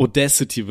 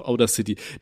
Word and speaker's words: Audacity: Audacity wird das tatsächlich ausgesprochen. Aber ich finde Outer oh, City richtig kacke, Alter. Ich nenne das auch Outer City.